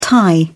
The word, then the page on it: thai: British English